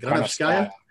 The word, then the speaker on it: Granovskaya: Marina Granovskaya, I can't pronounce it, Granovskaya. – Yeah, that's it.